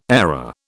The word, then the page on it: airer: British English